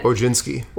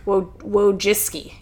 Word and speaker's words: Wojcicki: Wojcicki. – Wojcicki.